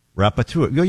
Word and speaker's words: rapporteur: How do you spell it? – Rapport. – Hold on. For National Dyslexic Week, go ahead. – R-A-P-P-O-R-T-E-U-R. – Rapporteur. You're close.